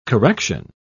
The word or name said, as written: correction